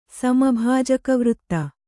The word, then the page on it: ಸಮಭಾಜಕವೃತ್ತ: Kannada